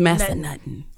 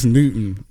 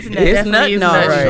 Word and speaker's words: Massanutten: Or is it Nutten? – It's Massanutten. – I was nut for real. – Yeah. – Whole time. I'm like, that cannot be right. We as a race have been pronouncing this wrong, and it is. I've been like, it's Newton, not Nutten. – It's Nutten. Oh, you Nutten today.